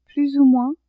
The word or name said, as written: peu plus ou moins